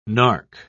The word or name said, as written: narc